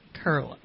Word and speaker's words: Koehle: So our guest is Orlean Kerle. Her last name is spelled K-O-E-H-L-E. And I won't do that again, but it is tricky because it doesn't spell like it sounds. And people usually pronounce it incorrectly.